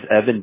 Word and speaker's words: Evan: My name is Evan Diaz.